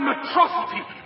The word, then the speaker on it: Metro City: This is a day you and Metrocity shall not soon forget. – It's pronounced Metro City.